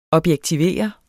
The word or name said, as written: objektivere